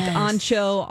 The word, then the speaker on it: ancho: So anything like ancho, arbol. Am I saying that right, Alexis? – Uh-huh. Yep.